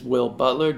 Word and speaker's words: Will Butler: My name is Will Butler, W-I-L-L-B-U-T-L-E-R.